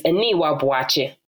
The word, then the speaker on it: Aniwa Boachie: In the same way, me, like how I would pronounce my name is Aniwa Boachie. That's how I would say it. But already you can hear that I kind of changed my accent slightly. So obviously being here, right, having a name like Aniwa as well.